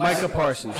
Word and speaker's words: Micah Parsons: Number 2 would have to be, I'm probably mispronouncing the boy's name, but is it Micah Parsons? Micah Parsons? – Micah Parsons.